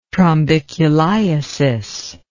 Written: trombiculiasis

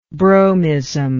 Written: bromism

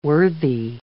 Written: worthy

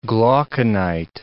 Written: glauconite

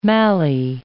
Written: mallee

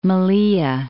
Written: Melilla

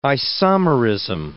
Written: isomerism

